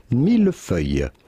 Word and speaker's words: millefeuille: In French, this would be pronounced millefeuille. Millefeuille. The S at the end of feuille is definitely silent.